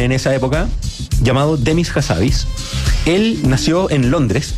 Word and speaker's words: Hassabis: Demis Hassabis. ¿Se dice así? Hassabis. – Hassabis. Es un personaje. – Habibi, así como Habibi.